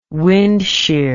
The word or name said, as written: windshear